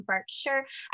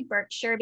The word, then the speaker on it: Berkshire: And then their new primary residence will now be Adelaide Cottage, which is in Berkshire. I always say Berkshire, being an American, I think they pronounce it Berkshire County.